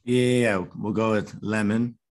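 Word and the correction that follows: Lehmann: Lemon. Love the pronunciation. Lemon in goal. – Exposing myself. Layman.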